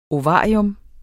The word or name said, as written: ovarium